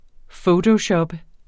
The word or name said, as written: fotoshoppe